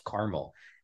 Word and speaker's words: Carmel: So there's a Carmel, California, and there's also a town called Carmel, Indiana, and believe me, it is pronounced Carmel.